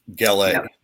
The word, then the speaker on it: Gallet: But the T is silent, Austin says. So, Gallet.